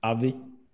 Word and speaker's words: OBVI: OBVI.